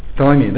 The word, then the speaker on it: Ptolemy: Ptolemy, да, я правильно сказал, отдал свою дочь.